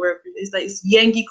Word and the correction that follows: Nengi: Coin. I'm not sure how you pronounce the name, but I really. – Yes, Nengi.